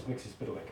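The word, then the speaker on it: Mxyzptlk: I'm using John Byrne's pronunciation. – What says that? – Which is Mixius Piddly.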